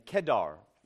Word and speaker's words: Kedar: and Kedar, the accent is on the first E.